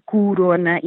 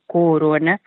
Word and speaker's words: coronavírus: As primeiras, as nucleares, foram naturalmente o coronavírus, e foi nessa altura que nós aproveitámos para sugerir ou recordar ou aconselhar a pronúncia correta de coronavírus, que deve ser com o inicial, deve ser corona e não, como continuamos a ouvir, corona.